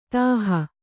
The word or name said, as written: ದೋಹಿ